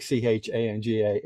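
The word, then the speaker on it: teanga: It is pronounced like Changa. It's an Irish word. It's in the Irish language.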